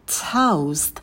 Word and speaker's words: toast: Toast. На «т» добавляем придыхание, потом дифтонг «эу».